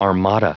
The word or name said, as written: armada